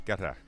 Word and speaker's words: Qatar: So, but the first syllable isn't a cute ka sound. It's more of a guitar. Think of guitar. Qatar. – Qatar.